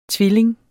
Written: tvilling

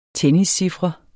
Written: tenniscifre

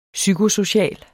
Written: psykosocial

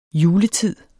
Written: juletid

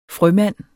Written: frømand

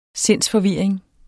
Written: sindsforvirring

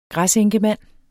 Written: græsenkemand

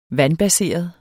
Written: vandbaseret

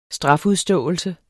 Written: strafudståelse

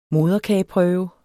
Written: moderkageprøve

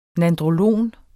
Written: nandrolon

